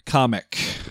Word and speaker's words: Kamek: The castle lands and an army of cute little Koopa troopers march out, led by a wizard Koopa named Kamek. That's it. That's how it's pronounced.